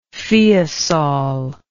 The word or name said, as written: feosol